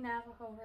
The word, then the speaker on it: Navajo: Am I saying Navajo right? – Yeah.